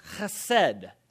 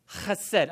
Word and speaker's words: chesed: Well, we don't have the symbol, but the Hebrew word translated in English is uh, that, and it's actually pronounced chesed chesed. I want you to say this with me. You got to use the little thing that hangs in the back of your mouth, the, the back of your tongue, the little, you know, the punching bag thing.